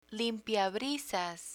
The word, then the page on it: limpiabrisas: Spanish